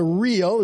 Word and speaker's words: riel: The Cambodian money is called the riel. It's spelled R-I-E-L. But you can really call it, it's real money.